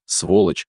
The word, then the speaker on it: сволочь: Перетащить, переволочить, переместить, сволочь, с ударением на втором слоге.